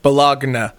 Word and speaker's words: baloni: Yeah, re- not spelled like baloney the meat. B a l o n i. Balagna.